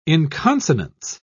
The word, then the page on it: inconsonance: British English